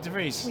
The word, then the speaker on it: De Vries: Kind of rhymes with French fries. – Nick De Vries.